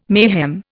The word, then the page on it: mayhem: Indian